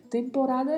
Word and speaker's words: temporada: Temporada é um substantivo que tem o mesmo significado no português, com sentido de época, de período, e a diferença de pronúncia é muito suave, né? – Temporada. Apenas o O mais fechado, o E mais fechado e a letra A mais aberta. Então pronunciamos temporada.